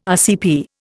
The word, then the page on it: RCP: Indian